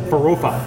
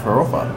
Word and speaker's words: Farofa: Farofa. – Farofa.